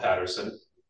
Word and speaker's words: Patterson: My name is Kirk Wiemet, spelled K-I-R-K. Last name is O-U-I-M-E-T. – Okay. – And then for the city, I'm Rob Patterson, P-A-T-T-E-R-S-O-N, city attorney and planner.